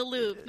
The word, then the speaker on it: Louvre: With the caption, Narcoleptics Anonymous met in the Louvre today. Or is that the museum? And I don't know how to pronounce it in French. – The Louvre, yeah.